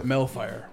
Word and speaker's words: Melfire: It's like Hellfire, but Melfire, right? – Okay.